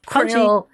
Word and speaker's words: corneal: So we've gone through pretty common corneal, sorry, I wrong emphasis on the wrong syllable. – Yep. – Corneal injuries.